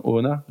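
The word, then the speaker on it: Oona: Il y, en a un que j'aime bien malgré tout, donc Oni Chaplin ou pardon Ona, j'écorche son nom.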